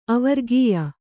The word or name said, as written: ಅವರ್ಗೀಯ